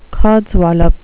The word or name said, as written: codswallop